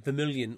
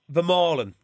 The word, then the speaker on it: Vermaelen: Vermaelen, that's a better pronunciation of it.